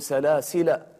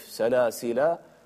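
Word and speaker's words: سلاسلا: طبعا هنا في مصحفنا سلاسل رسمت على كلمة سلاسل، آخر سلاسل الألف، هذا الصفر المستدير، وهذا إشارة هنا إلى أن هذه الألف لا تنطق، تقول سلاسل وأغلالا وسعيرا. وأيضا هناك وجه آخر لحفص في هذه الكلمة، يمكن أن تنطق الألف سلاسل وأغلالا وسعيرا، فهناك وجهان.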